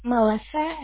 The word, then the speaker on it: Melissa: It's Melissa, M-E-L-I-S-S-A, last initial C as in Charlie.